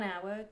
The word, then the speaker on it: hour: One hour, two hours, three hours, etc. On ne met pas le son H pour ce mot-là. Deuxième mot, HEIR, H-E-I-R.